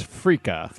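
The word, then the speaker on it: freekeh: The ancient grain I learned about today is frika.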